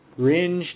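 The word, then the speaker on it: Rindge: From, how do you say that? Ringe, New Hampshire? Ringe, New Hampshire. All right. – Yeah, Ringe, New Hampshire.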